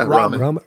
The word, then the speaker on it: ramen: They had some Rama and wines the night before. Is that what you pronounce it? Rama? What's that? The noodles? – Yeah, ramen.